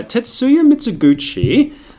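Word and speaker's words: Tetsuya Mizuguchi: Yeah, Tetsuya Mitsuguchi... I think I'm pronouncing that correctly. – Yep.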